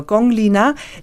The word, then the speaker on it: Gong Lina: Genau, Gong Lina, das ist sicherlich so das Chinesischste, was ich mitgebracht habe an Musik. Gong Lina hat eine Ausbildung gemacht zu einer klassischen Opernsängerin, also Peking-Oper.